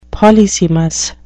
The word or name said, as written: polysemous